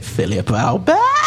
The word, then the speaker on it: Philippe Albert: To say his name right, like Martin Tyler says it, Philippe Albert.